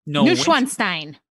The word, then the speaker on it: Neuschwanstein: Neuschwanstein. – How did you not mention this is in Soren? – Oh, because I don't know that. – It is in Soren. Neuschwanstein Castle.